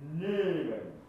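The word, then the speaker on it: Neben: A の音が、えい、えいというふうに、やや E に近く、こっち少し上で発音される感じ。ネーベン。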